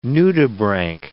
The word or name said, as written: nudibranch